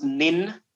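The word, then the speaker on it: nin (忍): It's pronounced nin. N-I-N, nin, and it means like persistence, perseverance, like steady improvement.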